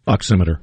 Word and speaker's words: oximeter: oximeter. Is that how you say it? – Oximeter. – Oximeter. I said it right the first time.